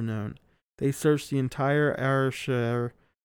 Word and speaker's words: Ayrshire: Ayrshire, along with a small army of 400 armed men and a pack of tracker dogs.